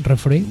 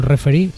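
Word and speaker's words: Refree: Y el otro día salía en la música Raúl Refri. Sí. No sé si lo estoy pronunciando bien. Referee. – Bueno, como el árbitro, referee.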